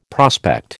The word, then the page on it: prospect: American English